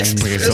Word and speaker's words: Espresso: Und ein Expresso dazu. – Ein Espresso.